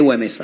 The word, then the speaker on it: Güemes: Es el paso a la inmortalidad del general Martín de Güemes. Ahí está, ahí lo dije bien.